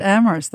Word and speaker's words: Amherst: Masha's also now a professor at Amherst, is that right? – That's right. – Or Amherst, I always mispronounce it. At Amherst, that's right. You don't pronounce the H.